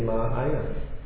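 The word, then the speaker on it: Maaya (माया): Yeah, Ma with a long A, actually if you break it down, it's actually Maaya, my mercy.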